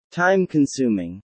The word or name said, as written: time-consuming